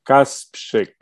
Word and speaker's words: Kasprzyk: Kasprzyk.